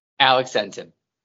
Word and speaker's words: Alex Entin: I'll let you introduce yourself because I don't want to say your last name wrong. So go ahead. – Alex Entin.